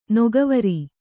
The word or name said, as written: ನೊಗವೊರು